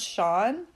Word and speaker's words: Shan: She's 19 years old, and her husband is Shan, which I think they pronounce Sean, but it's spelled S-H-A-N, Shan.